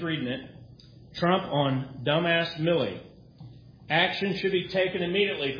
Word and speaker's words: Milley: Is his name pronounced Miley or Millie? – Millie Vanilli.